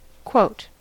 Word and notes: quote: American English

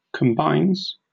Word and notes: combines: British English

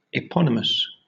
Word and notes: eponymous: British English

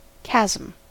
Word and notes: chasm: American English

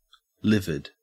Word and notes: livid: Australian English

